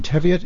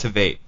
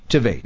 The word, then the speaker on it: Tveit: In the show, Aaron Teviot, is that how you say it? – I think it's Tveit. – Tveit.